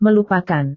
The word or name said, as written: melupakan